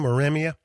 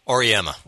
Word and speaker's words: Auriemma: And he paid homage to, I believe, Jim Calhoun and Gino, or how do you say Gino's last name? Auremia? – Auriemma.